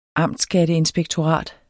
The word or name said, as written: amtsskatteinspektorat